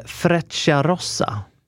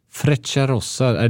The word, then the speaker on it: Frecciarossa: Men jag tror att man uttalar Frecciarossa. – Frecciarossa, det känner inte jag till.